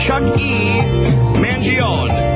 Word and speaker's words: Mangione: There's a long E on the end of this guy's name, so I stand corrected. Here is Chuck E. Mangione.